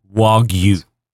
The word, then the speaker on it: Wagyu: I just want you to say right now to all your audience, I've been pronouncing it wrong. – It's Wagyu. – It's Wagyu. – There you go.